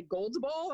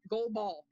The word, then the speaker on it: goal ball: And I'll spell it, because people say gold ball. I'm like, no, goal, G-O-A-L, goal ball.